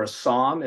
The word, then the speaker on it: psalm: And I'm looking, and I want to correct me if I'm not saying this correctly, but looking for a psalm. Is it a psalm? Like they have all the numbers. – Yep. A psalm.